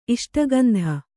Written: ಇಷ್ಟಗಂಧ

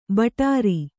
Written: ಭಟಾರ

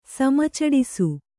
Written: ಸಮಚಡಿಸು

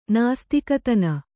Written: ನಾಸ್ತಿಕತನ

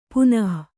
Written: ಪುನಃ

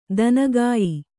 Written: ದನಗಾಯಿ

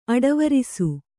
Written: ಅಡವರಿಸು

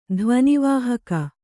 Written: ಧ್ವನಿವಾಹಕ

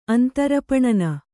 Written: ಅಂತರಪಣನ

